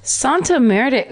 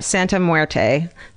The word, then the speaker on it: Santa Muerte: and had a shrine to Jesus Malverde and Santa Muerte, who were, I said that wrong. Listen, I'm Jewish. Don't, I can't. – Santa Muerte. Muerte? Muerte sounds like death, right? – Santa Muerte.